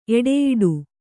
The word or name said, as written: ಎಡೆಯಾಡು